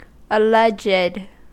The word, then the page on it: alleged: American English